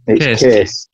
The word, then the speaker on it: Kees: I want to say Keys. How's it pronounced? K-E-E-S? – Keys. – No. Is it pronounced Keys? Keys. – Kiss.